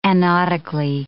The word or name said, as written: anodically